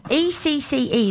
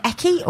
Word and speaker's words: Ecce: How do you say this? ECCE. ECCE. Is it Ecce or Ecce? Ecce Media.